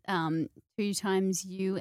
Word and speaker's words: 2XU: We're going to be, joining forces with 2x you and Beyond Blue for the wellness run. Did I say that correctly? – Semi, you did, yeah.